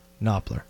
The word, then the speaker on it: Knopfler: Did I say Knopfler? Knopfler. – Why did I think there was an F in there? – Why did I think there was an F in there, too? – Did they spell it wrong on the thing?